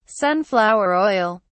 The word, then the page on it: sunflower oil: British English